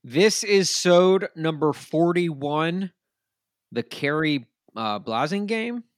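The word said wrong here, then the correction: Khari Blasingame: Kari Blasingame. Hey, there's a Kari. – Oh, gosh. Oh, geez. Here I am to hosting a Titans podcast, and I just mispronounced his name. Kari Blasingame.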